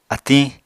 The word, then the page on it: atiñ: Apatani